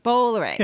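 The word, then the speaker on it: Bolloré: Bollore. – Yes. So Bollore is almost a 200-year-old conglomerate yeah. – run by the sixth generation of his family, Vincent Bollore.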